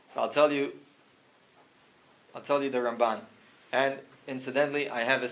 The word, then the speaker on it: Ramban: Rambam, the stress is on the first syllable. Rambam, mil el, and then the Ramban, mil ra, it's on the second syllable. Rambam, Ramban.